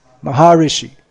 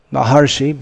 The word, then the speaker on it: Maharshi: Maharishi, they called him. Actually, the pronunciation is Maharshi, but they pronounce it Maharishi.